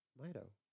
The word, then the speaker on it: Mido: M-I-D-O. How would you say that? – Mido. – Yeah, it's Mido, but now you're thinking Saria?